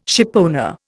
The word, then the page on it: shipowner: Indian